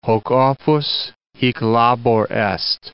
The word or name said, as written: hoc opus, hic labor est